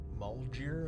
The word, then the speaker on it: Maljdir: Malgir, I think is how it's pronounced. M-A-L-J-D-I-R.